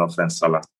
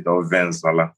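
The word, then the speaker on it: ofe nsala: So I really don't have a favorite, but my go-to, like, celebration food, like, is pounded yam and offen salad. Now, if I'm going to say it how we pronounce it, ovensala. Like, we have a way of pronouncing, you know, words.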